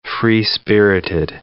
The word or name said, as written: free-spirited